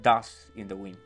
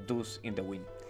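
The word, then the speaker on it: Dust in the Wind: Das in the Wind, que yo lo pronuncié mal y obviamente el, profesor me dijo, mira, es Das in the Wind, no Dus in the Wind.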